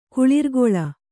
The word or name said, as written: ಕುಳಿರ್ಕೊಳ್